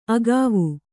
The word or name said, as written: ಅಗುವು